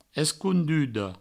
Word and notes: esconduda: Provençal; escoundudo

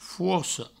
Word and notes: forca: fouarco

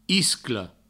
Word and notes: iscla: isclo; Provençal

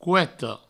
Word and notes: coeta: coueto

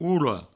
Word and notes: ola: oulo

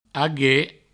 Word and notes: aguer: ague

